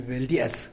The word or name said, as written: beldias